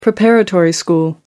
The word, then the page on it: preparatory school: American English